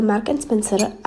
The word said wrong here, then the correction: Marks & Spencer: a já to nikdy neumím vyslovit, protože my to doma vždycky s mamkou zkolomolíme. Tak je to asi Marks & Spencer. Mělo by to tak být.